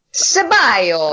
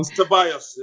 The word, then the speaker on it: Ceballos: Who couldn't be excited about Pepe and, how do you say his name? Salibos? I don't know. – Ceballos. – Oh, Ceballos. I get it all wrong. – Ceballos, yeah.